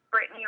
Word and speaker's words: Brittni: I have Instagram, which is Brittany Ruiz.